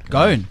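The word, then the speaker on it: Ghosn: how do you pronounce that? – Goshen. – Goan. – Yeah.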